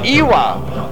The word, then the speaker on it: Iwa: Thus we say Waikiki in regular W pronunciation, but we say Iwa as though it were spelled Ava.